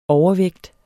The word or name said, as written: overvægt